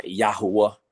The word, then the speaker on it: Yahuwah: And then I was like, how do you pronounce it? And I heard, and I ran into some Israelite brothers. It was like, Yahuwah.